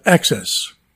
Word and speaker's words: AHCCCS: or AHCCCS, pronounced access.